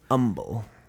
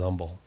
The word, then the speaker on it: Humble: No H. I don't pronounce the H on Humble. – Humble. – Oh. – I used to live in Humble, and that's what I called it, was Humble.